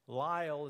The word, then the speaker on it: Liele: Lyle is spelled L-I-E-L-E.